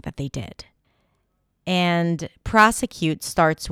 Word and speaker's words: prosecute: Okay, the next word is prosecute. Prosecute. The primary stress here is on the first syllable, pra, pra, pra.